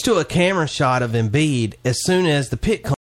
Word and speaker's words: Embiid: Embiid. Is that how you pronounce it? – Joel Embiid. – Yeah.